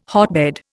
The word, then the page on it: hotbed: Indian